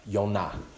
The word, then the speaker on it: Yonah: Jonah, the Hebrew name Jonah means dove, Yonah. So it's easy for me to remember when I speak Hebrew because I know what Yonah means, dove. So, and that's how you pronounce Jonah in the Old Testament.